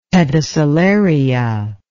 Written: pedicellaria

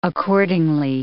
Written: accordingly

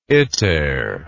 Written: iter

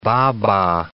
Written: baba

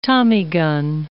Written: tommy-gun

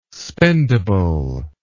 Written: spendable

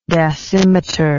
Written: dasymeter

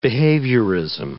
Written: behaviorism

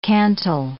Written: cantle

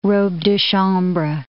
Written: robe de chambre

